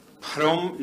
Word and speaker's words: Phrom: And Phrom is simply the local pronunciation of Brahma.